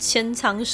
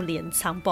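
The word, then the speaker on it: 鎌倉: 狗的历史的时候，我好像是念成千仓时期，因为我一直以来我都不大哈确定它怎么念，一直直到我必须要录这个 podcast，我才去查这个资料，它念错镰仓，不好意思啊。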